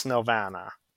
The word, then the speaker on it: Nelvana: I was pronouncing it wrong. Is it Nelvana? – I believe it's Nelvana.